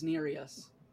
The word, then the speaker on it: Nerys: I thought it was Nerys. – Nerys. – That's how they pronounce it in the audiobook, is Nerys. – That's fair. Nerys it is.